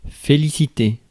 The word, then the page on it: praise: /pɹeɪz/